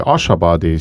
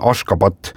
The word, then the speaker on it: Ashgabat: Nende pealin, mida mina mäletan tõesti kui Ashabadi, sina ütlesid enne mingi teise nime. Sinna ka sotsite. – Jaa, selle pealinna tänapäevane õige kirjapilt ja hääldus on Ashgabat ja siis 1991.